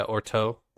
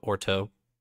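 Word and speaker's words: Urteau: Yeah, Urto. They pronounce it with more of an O than a U in the book, okay. So Urto.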